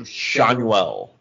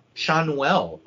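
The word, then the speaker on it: Schanuel: Shanuel. – Shanuel.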